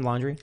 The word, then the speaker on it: Laundrie: Laundrie? – Oh, yeah.